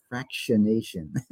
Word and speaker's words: fractionation: Fractionization or something. – Fraction. Oh my goodness. – Did I say that wrong? Now I screwed you up. I'm sorry. – It's fractionation.